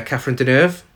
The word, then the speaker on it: Catherine Deneuve: Played by Catherine Deneuve.